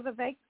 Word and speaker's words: Vivek: You know, Vivek's one of those who's, isn't that how they're pronouncing his name? – Yes.